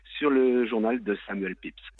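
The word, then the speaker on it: Pepys: Là, moi, en le refermant, je me suis jeté sur le journal de Samuel Pépiz, qui paraît-il, on doit prononcer Samuel Pips,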